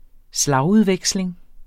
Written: slagudveksling

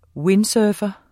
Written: windsurfer

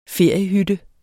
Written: feriehytte

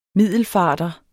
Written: middelfarter

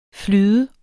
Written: flydende

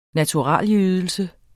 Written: naturalydelse

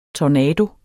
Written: tornado

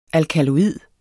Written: alkaloid

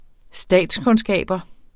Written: interessere

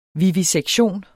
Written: VIVISEKTIONERNES

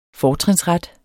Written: FORTRINSRETTEN

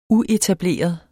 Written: uetableret

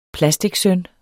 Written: plasticsøn

